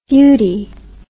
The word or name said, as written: futy